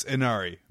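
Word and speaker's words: Ynnari: It's Inari. The Y's are pronounced as I's.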